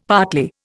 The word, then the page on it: partly: Indian